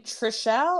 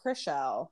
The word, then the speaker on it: Chrishell: And so and is her name Trishelle or Chrishell? Chrishell, Chrishell.